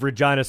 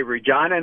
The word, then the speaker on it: Regina: They say Regina, not Regina, yeah.